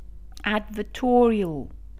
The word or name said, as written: advertorial